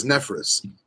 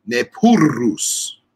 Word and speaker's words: Nephrurus: everyone says Nephrus. It's not Nephrus. It's Nephrurus.